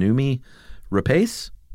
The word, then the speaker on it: Noomi Rapace: Old friend Rebecca Robe again says, I don't know how you pronounce her name, Noomi Rapace? – That's how I've always pronounced it.